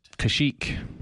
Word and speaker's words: Kashyyyk: Kashyyyk with three Ys, I believe.